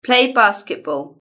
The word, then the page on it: play basketball: British English